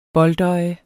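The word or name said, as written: boldøje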